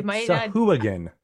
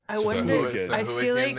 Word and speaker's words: Sahuagin: He just said Sahuagin. – I'm going after what Mr. Matthew Murther says on the pronunciation guide on D&D Beyond and Chris Perkins, which say Sahuagin. – Sahuagin it is then.